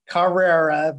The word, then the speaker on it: Cahrera: So on Instagram, it's 993 Carrera, but it's spelled C-A-H-R-E-R-A. So it's Carrera.